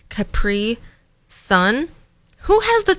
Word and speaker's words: Capri Sun: So in Ashley's speech, I pronounce it the same way you would pronounce a Capricorn. So a Capri Sun, you know, like Capricorn, Capri Sun, instead of Capri Sun.